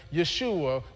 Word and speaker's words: Yeshua: So we hear that, we see that the name that Jesus was given, we see it in the English as Jesus, but the actual pronunciation was Yeshua, which is the short version of the Hebrew Yehoshua.